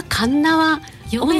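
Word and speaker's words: 鉄輪: つは別府、皆さんよくご存知だと思うんですけど、別府温泉の中の神奈川温泉、こちらがあんまり馴染みがないんじゃないかなと。 – なんか名前はなんとなく聞いたことがありますね。はい、読みにくい鉄っていう字に、輪っかの輪って書いて、あれなかなか神奈川温泉って読めないですよね。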